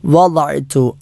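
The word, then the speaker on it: വദഅ്തു (وضعت): റബ്ബി വദഅ്തു, അതാണ് നമ്മൾ ഉച്ചാരണത്തിൽ ശ്രദ്ധിക്കേണ്ട പദങ്ങൾ. വദഅ്തു, വദ എന്നത് ദ നാവ്, ഇടതുഭാഗം ഇടതുപല്ലിലോ അല്ലെങ്കിൽ വലതുഭാഗം വലതു പല്ലുകളിലോ സ്പർശിച്ചുകൊണ്ട് ഉച്ചരിക്കേണ്ട അക്ഷരമാണ് ദ എന്നത്.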